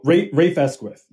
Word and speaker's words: Rafe Esquith: One thing I was impressed by, and I'm not sure how to even pronounce his name, but is it Rafe Esquith? – Oh, Rafe Esquith, yeah.